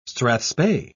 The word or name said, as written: strathspey